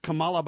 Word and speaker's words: Kamala: Tucker Carlson refuses to pronounce her name right. It's Kamala, by the way.